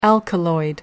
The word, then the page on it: alkaloid: American English